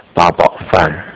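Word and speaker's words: Ba Bao Fan (八宝饭): One of them is Eight Treasure Rice in Mandarin. That's pronounced Ba Bao Fan.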